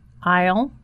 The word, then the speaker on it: aisle: Aisle or window? – What kind of seat would you prefer? Prefer는 P-R-E-F-E-R. 뭔것을 더 좋아하다 이런 뜻이죠. Aisle or window. I-S-L-E, aisle. I-S-L-E, S가 발음이 되지 않습니다.